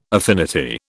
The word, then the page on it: affinity: British English